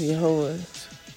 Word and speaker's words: Yahweh: Now today, it's generally accepted pronunciation for ease of reference is Yahweh or Yahowah, Va and W in Hebrew, U and W.